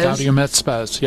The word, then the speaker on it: Gaudium et Spes: And then the Catechism quotes Gaudium et Spes. Do I pronounce that correctly? – Yep. Spes? Gaudium et Spes, yep.